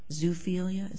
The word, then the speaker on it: zoophilia: putting undue emphasis on this underlying crime of zoophilia? Is that how you say it? – Zoophilia, Your Honor.